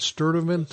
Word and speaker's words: Sturtevant: Did I say that right? Sturtevant? – That's right.